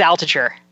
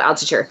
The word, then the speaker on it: Altucher: I don't know if I'm saying his name right. How do you say his name? – Yeah, James Altucher. – Oh, Altucher. He's a rock star.